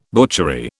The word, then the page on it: butchery: British English